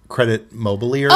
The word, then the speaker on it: Crédit Mobilier: The Crédit Mobilier scandal. – What? – Essentially, if I was trying to say this in American English, it would be credit mobilier.